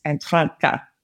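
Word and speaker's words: Anthwerrke: The English name for it is Emily Gap, and the Aranda name for it is, you have to roll your R's, so it's really hard, but it's Antranka.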